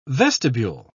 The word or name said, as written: vestibule